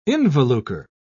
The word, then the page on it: involucre: British English